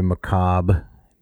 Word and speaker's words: macabre: You know, the very macabre. Did I pronounce that right? – I think you did.